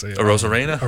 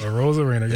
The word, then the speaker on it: Arozarena: You know, Naylor and is, I don't know how to say his name, like Arozarena. – Randy? Arozarena, yes.